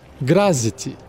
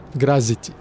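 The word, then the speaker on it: Graz'zt: Grazit, que se escreve G-R-A-Z apóstrofe Z-T. Grazit.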